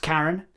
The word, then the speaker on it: Carion: Well, it's pronounced Karen. – Yeah. – It's spelled Carion.